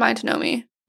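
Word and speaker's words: Miantonomi: So they – so it was Canonicus and Mayantonomy, I believe is how you say it correctly.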